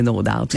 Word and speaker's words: No Doubt: Alors, c'est confirmé, c'est No Doubt.